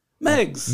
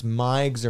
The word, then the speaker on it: Megs: It's pronounced the way it's spelled. You'd think it's Migs or Meigs. – Yeah. – You wouldn't think Megs.